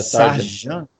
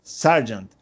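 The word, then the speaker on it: Sargeant: Bom, o José Zucolo, ele está perguntando se o Logan Sargent, como é que fala o sobrenome? É, Sargent. – Sargent.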